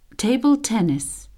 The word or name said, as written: table tennis